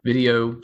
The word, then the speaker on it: Vidyo: It's called Vidyo, but it's spelled V-I-D-Y-O is the application.